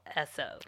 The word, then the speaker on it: Esso: And the only other thing I had written down is that the phonetic pronunciation of Standard Oil, Esso. – Right. – And then it's spelled ESSO, which caught my...